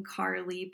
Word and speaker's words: Carlie: You can search The Christian Sex Educator or just put in my name, Carly Palmer Webb. My name is spelled C-A-R-L-I-E, which I guess will be in the notes.